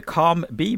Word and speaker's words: COM-B: Do you call it the COM-B model? Is that the correct pronunciation of it? – Yes, yeah, that's actually a model that was developed by researchers at University College of London.